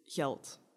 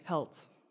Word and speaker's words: geld: So if you go more towards the coast, they don't pronounce their G's. Their G's become H's. So, for example, money is geld. So that's a formal way, geld, for money. And then they would say held, or something like that.